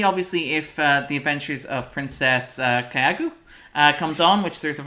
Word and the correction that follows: Kaguya: It's Princess Kaguya. – Princess what? – Kaguya. – Kaguya. Princess K-A-G-U-Y.